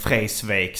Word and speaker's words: Vreeswijk: Eller Vreeswijk som han själv uttalade.